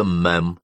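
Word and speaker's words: Ma'am: Королеву мадам, произносится мэм.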